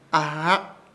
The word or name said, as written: AHRAK